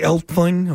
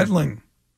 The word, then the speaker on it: Etling: Etling.